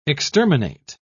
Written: exterminate

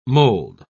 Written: mold

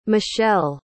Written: machelle

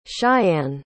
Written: cheyanne

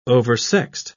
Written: oversexed

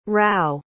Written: rao

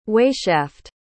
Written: weighshaft

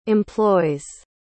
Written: employs

